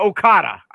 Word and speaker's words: Okada: But Okada. I know Okada. I can pronounce that part.